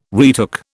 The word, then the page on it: retook: British English